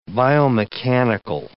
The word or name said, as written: biomechanical